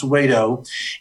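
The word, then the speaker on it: Wedow: It's W-E-D-O-W, but pronounced Wado.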